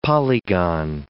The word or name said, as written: polygon